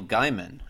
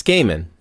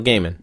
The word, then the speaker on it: Gaiman: It's Gaiman. It's Neil Gaiman.